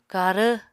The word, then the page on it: karv: Apatani